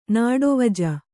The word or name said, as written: ನಾಡೊವಜ